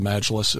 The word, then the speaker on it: Majlis: the Majlis. Am I pronouncing that correctly? – Correct.